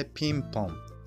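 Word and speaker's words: ping-pong: ピンポンピンポンピンポンはい OK です、えー、卓球のスペルは最後にピングポングというふうに、えー、なっていますけれどもこの最後の G は発音しませんほぼ最後に G が来るときは発音しないことが多いですですのでピンポンと発音する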